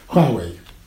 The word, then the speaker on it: Huawei: Do I pronounce that right or wrong? – Huawei.